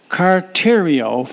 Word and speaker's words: kartereo: And of course, the Greek word, which if pronounced in English, would sound like kartereo for the word endured. It gives us the idea of steadfastness.